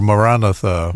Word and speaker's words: Maranatha: Maranatha or Maranatha, as it should be pronounced, and probably never will be with the Baptist people.